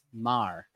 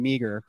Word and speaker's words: Meagher: You know, I would have said Harrison Meagher. For those of you guys who are watching the here, you can see it looks like Meagher. I've got other Irish friends who don't live in Australia, and they pretty much just pronounce it as exactly as it's spelled.